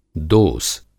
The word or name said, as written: दोस